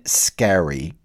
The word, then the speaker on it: Scarry: I want to divert out into a book that Elaine Scarry, her name is spelled S-C-A-R-R-Y, but pronounced Scarry.